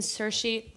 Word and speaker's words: Saoirse: um, so our first discussion today is the trailer for Francis Lee's new film Ammonite, starring Kate Winslet and Saoirse Ronan which I'm very proud of myself because I've never been able to pronounce her name You've right.